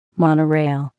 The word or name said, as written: monorail